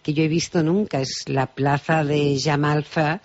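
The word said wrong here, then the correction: Jemaa el-Fna: Está bien, pues es la plaza famosa que se llama Jamalfna, que es muy conocida a nivel internacional y está declarada como patrimonio oral, declarado por la UNESCO.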